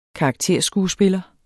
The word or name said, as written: karakterskuespiller